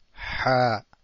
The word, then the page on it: ح: Ha